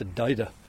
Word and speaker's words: Dagda: And it's the cauldron of the Dida. Now, I believe that is the correct pronunciation. It's usually spelled D-A-G-D-A. You'd pronounce it Dagda if you were looking at it.